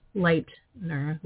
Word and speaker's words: Leitner: It's L-A-I-R-E, Leitner, like L-I-G-H-T, Leitner.